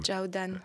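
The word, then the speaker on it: Zhao Dan (赵丹): Zhao Dan.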